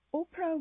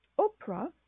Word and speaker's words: Oprah: So Oprah, the name Oprah, has P, R, and H in as consonants in her name. H at the end is silent, but there is a touch of it.